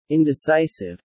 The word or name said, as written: Indecisive